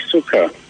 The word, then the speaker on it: Suka: My name is Suka. – Suka? – Yes. – That's your real name? – Yeah, my real name. – How do you spell it? – S-U-K.